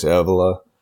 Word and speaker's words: Evola: I don't know if I'm pronouncing that right. Julius Evola. – Yes.